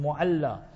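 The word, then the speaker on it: Mu'alla (المعلاة): I tell the brothers that normally on a Friday we go, we're going there to Mu'alla, learn proper pronunciation, Mu'alla. So Mu'alla, remember, then you'll find 5, 10 brothers.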